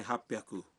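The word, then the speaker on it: ہاپ پیاکو (八百): مثلاً 300 ہے سام پیاکو، 600 ہے روپ پیاکو، اور 800 کو کہتے ہیں ہاپ پیاکو.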